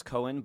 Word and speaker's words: Coan: My last name is pronounced Cohen, but it's C-O-A-N. – And we got the clue. – You got a clue. But people pronounce C-O-A-N wrong all the time, and I like it does.